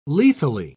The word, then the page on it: lethally: British English